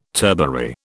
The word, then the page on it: turbary: British English